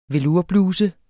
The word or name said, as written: velourbluse